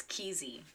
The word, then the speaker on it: Kesey: I'm pretty sure it's Kesey.